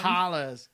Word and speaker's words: holler: Hollers. – No, holla. No. Not holla. Holler. Holler. Like you pronounce the er, but that's neither here nor there.